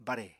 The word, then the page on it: bare: Apatani